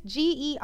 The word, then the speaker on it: Gerewol: It's Gerewol. Oh, seriously. So sorry that I mispronounced that.